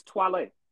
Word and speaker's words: Toilet: Her name is Twale. Like Wale with a T on it.